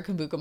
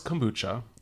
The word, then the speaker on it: kombucha: I wrote either a yoni egg or any cleanser or kombucha mimosa. – You, first of all, it's kombucha. – Kombucha. Oh, God, I know. You've been saying it right this whole time.